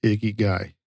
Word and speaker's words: ikigai: Also, if you want to look us up for yourself on the internet, Icky Guy is actually spelled IKIGAI. – That don't make no sense. – Yeah, that's how it's spelled. It's pronounced Icky Guy.